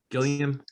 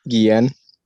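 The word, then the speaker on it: Guillén: Harley Quinn season 3, the voice of Nightwing will be how do you say his last name? Harvey Gilliam? – Guillen.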